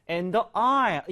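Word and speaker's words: aisle: 그다음에 I, S가 묵음인 게 중요해요. I라면은 여러분 통로 복도라는 뜻인데요. 그 항공기 승무원이 I에 서 있다. The flight attendant is in the I, in the I 이렇게 보시면 되겠습니다.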